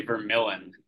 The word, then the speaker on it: Vermylen: Mark, Vermylen, or is it Vermylen? – It's actually Vermylen.